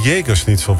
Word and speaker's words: Jägerschnitzel: Het is dan misschien de Jägerschnitzel, want zo spreek je dat.